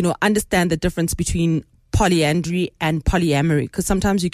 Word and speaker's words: polyandry: Okay, so we're going to start with polyandry. So it's poly and dry. So if you pronounce it like that, if you break it up, mm-hmm.